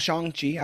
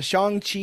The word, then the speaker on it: Shang-Chi: Shang-Chi, I've actually been corrected. I'm glad I know the actual pronunciation now. Shang-Chi